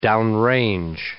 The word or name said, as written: downrange